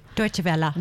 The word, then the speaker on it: Deutsche Welle: I'm so excited, and I want to congratulate on her recent acceptance into a Deutsche Wells program in, or Deutsche Welle, I think that's the right way to say it, isn't it? – Deutsche Welle. – Deutsche Welle, thank you.